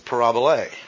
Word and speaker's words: parabole: And that's a P, that's an A, R, A, B sound, O sound, L sound, and a long A sound. So the Greek word for parable is parabole. And our English does what? It just comes across with P-A-R-A-B-L-E.